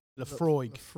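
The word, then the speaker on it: Laphroaig: LaFroy.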